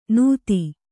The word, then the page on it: ನೂತಿ: Kannada